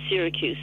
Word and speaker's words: Siracusa: Anyway, I had been in Syracusa... or Syracuse, if you pronounce it the English way, is the old section is just unbelievable looking.